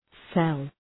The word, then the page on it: cell: sel